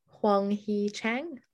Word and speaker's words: Hwang Hee-chan: And then debutants, Hwang Hee-chan, that's how you say it.